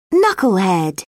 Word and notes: knucklehead: British English

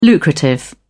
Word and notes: lucrative: British English

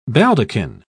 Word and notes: baldaquin: British English

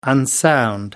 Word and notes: unsound: American English

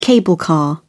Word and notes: cable car: British English

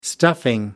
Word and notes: stuffing: American English

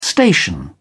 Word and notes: station: British English

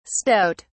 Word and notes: stoat(s): British English

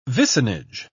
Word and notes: vicinage: British English

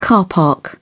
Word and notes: car park: British English

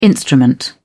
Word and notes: instrument: British English

